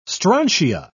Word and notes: strontia: British English

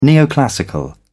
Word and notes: neoclassical: British English

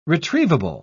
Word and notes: retrievable: British English

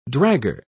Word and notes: dragger: British English

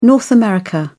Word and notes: north america: British English

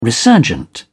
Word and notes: resurgent: British English

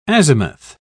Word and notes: azimuth: British English

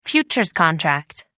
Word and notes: futures contract: American English

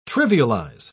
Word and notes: trivialise: British English

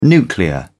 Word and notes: nuclear: British English

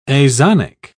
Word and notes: azonic: British English

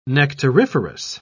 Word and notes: nectariferous: British English

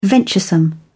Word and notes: venturesome: British English

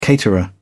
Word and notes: caterer: British English

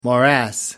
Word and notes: morass: American English

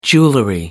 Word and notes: jewelry: British English

